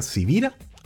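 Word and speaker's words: Sibira: Mi nombre es Carlos Javier Civira. Civira se escribe con S de Samuel, con B de Victoria.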